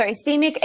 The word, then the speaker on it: ischemic: And I saw that 85% of them are, is it pronounced ischemic? – Ischemic strokes.